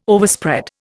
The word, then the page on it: Overspread: Indian